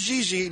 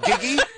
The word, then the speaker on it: Gigi: It's pronounced Gigi, not Giggy.